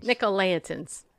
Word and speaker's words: Nicolaitans: and also the teachings of the Nicolaitans. Did I say it right? – Nicolaitans.